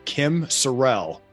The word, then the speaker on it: Kim Sorrelle: Today, I am so excited to have Kim Sorrelle.